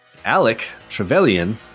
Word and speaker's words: Trevelyan: Alec Trevelyan.